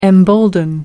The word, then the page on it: embolden: American English